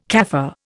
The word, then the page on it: Kaffir: Indian